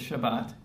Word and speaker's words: Shabbat: Okay, in Hebrew you pronounce it Shabbat.